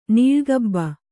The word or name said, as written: ನೀಳ್ಗಬ್ಬ